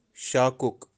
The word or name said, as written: shakuk